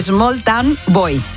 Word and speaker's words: Small Town Boy: El primero fue ese maravilloso Small Town Bow. Small Town Bow. Hoy voy, ahí se lo digo bien. – A ver. – Small Town Boy.